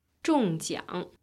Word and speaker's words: 中奖: 中奖。